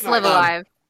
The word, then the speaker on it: Live A Live: I still don't know how to pronounce it. – It's Live Alive.